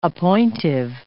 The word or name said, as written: appointive